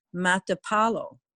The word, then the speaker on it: Matapalo: Surfers like to go to Playa Matalpo, and that's M-A-T-A, I mispronounced it, Playa Matapalo. – Matapalo. – That's Playa Beach, and that's spelled M-A-T-A-P-A-L-O.